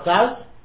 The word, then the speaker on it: Klaus: The Klaus? Klaus, really, is close. It's pronounced. It's the base matters where people sit and learn all day.